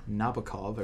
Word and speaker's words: Nabokov: And that film is 1962's Lolita, which was based off of the novel of the same name, written by Vladimir Nabokov.